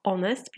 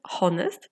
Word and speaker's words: honest: Na przykład słówko honest piszemy hy, ony, e ty przez samo h, ale nie wymawiamy honest, tylko honest, że ktoś jest uczciwy. Więc możesz sobie pomyśleć, że to słowo czytamy honest, a to tak naprawdę jest honest.